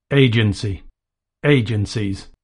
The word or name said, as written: agency